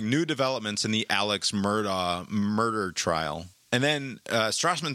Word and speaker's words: Murdaugh: The way that the normal English pronunciation of it would be Alex Murdaugh. His family pronounces it, he has chosen or whatever to pronounce it, Alec Murdaugh, which is an entirely different situation.